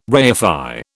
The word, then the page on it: reify: British English